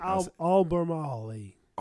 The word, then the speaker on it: Albemarle: Albemarle.